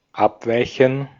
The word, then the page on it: abweichen: [ˈʔapˌvaɪçən]